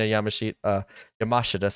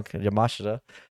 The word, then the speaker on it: Yamashita: Yamashita. Sorry, I've been always saying her name wrong. Yamashita.